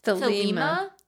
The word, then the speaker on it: Thelema: Thelema? – Thelema.